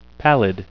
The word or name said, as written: pallid